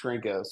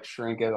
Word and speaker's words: Chrenko: So my last name is pronounced Chrenko. So you can imagine I got all kinds of terrible nicknames in high school from that. So, but yeah, like Chrenko, like it's spelled with a CH though.